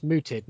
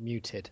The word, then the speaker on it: mooted: When you say mooted, you mean August was silenced or it was suggested? – It was suggested. Mm-hmm. It was mooted, not muted. – Right. – There's a slightly different vowel sound there.